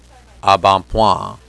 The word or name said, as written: embonpoint